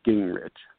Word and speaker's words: Gingrich: I actually try not to use my last name because it's hard for me to pronounce as well. But I usually suggest people not look at it because it's easier to pronounce if you don't look at it. It's Gingrich.